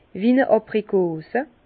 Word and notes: wie-n-e Abrikos: Bas-Rhin; Herrlisheim

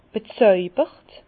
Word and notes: bezojbert: Bas-Rhin; Herrlisheim

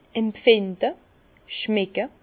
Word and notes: empfinde, schmecke: Bas-Rhin; Herrlisheim

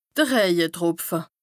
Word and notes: de Rëjetropfe: Bas-Rhin; Strasbourg

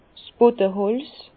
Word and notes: 's Bödeholz: Bas-Rhin; Herrlisheim

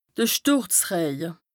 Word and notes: de Stùrzrëje: Bas Rhin; Strasbourg

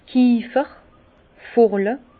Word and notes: d' Kiefer, Furle: Alsatian; Bas-Rhin; Herrlisheim

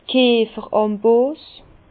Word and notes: d' Kierferambos: Bas-Rhin; Herrlisheim

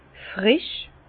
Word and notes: frisch: Bas-Rhin; Herrlisheim

